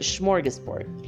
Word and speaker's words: smorgasbord: There's no SH, but that's how you pronounce it, smorgasbord.